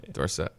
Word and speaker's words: Dorsett: Is it Dorsett or Dorsett? – Dorsett. – Until it was Dorsett before he won the Heisman Trophy.